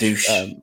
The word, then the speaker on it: Dusch: And Dusch. – It's spelled like duck. – Yeah. – Isn't it? But like, pronounced douchebag.